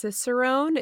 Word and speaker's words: Cicerone: Okay, so before we go into the questions about Stemma, I did want to ask you about the advanced Cicerone. Is that how you pronounce it? – Yeah, that's correct.